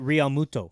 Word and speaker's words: Realmuto: JT Realmuto. – Realmuto.